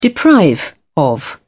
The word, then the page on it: deprive: British English